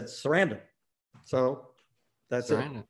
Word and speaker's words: Sarandon: The original name, when my father came to this country, was Sarandonidis. – Sarandonidis. – So the missing syllable was the emphasized syllable. And so when they shortened it, I'm assuming they did it maybe at Ellis Island or someplace. So when my father said it, he emphasized every syllable.